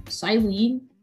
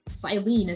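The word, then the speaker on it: Cyllene: So the thing is that, and a lot of people have observed this, is that Silene greatly resembles the character of Cyrus, the villain, main villain of Diamond, Pearl, Platinum, and the leader of Team Galactic.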